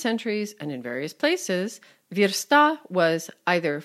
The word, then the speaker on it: versta: The other headache is stress and pronunciation. Singular, versta. Plural, versti.